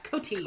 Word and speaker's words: Koty: Did you notice his name is spelled K-O-T-Y? – Yeah, Coty. – Coty. Like a tampon, like Kotex.